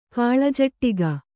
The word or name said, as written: ಕಾಳಜಟ್ಟಿಗ